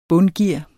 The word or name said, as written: bundgear